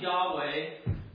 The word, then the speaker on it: Yahweh: So that word there would be more properly pronounced Yahweh.